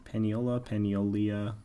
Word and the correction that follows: Paniola: I can never pronounce it correctly. – Paniola. We're going to stick with Paniola.